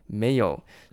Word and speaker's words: Mayo: 第一个呢，在修正理论时期的有没有念书呢？没有。诶，这个 M A Y O 真的念没有？不信你自己去 Google 上面打，你打 M A Y O，中文翻成没有，但是呢，英文真的念作没有。